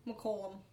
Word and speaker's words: McCollom: McCollom.